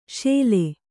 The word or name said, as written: ಶೆಲೆ